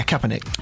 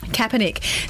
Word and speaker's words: Kaepernick: Rihanna turned down the opportunity to perform at next year's Super Bowl halftime show in the support of Colin Kaepernick. Did I say his name correctly then? – Yeah, Kaepernick. – Kaepernick.